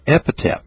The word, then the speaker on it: epithet: It's an epithet of Jesus Christ. What? What? It's an epithet, E-P-I-T-H-E-T. Epithet. That's the way you pronounce it.